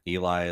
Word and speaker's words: Eli: Eli is spelled E-L-I.